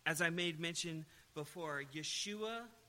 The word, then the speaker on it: Yeshua: Now, in the Hebrew, it's Yeshua. Jesus is a transliterated Greek version of the Hebrew Yeshua.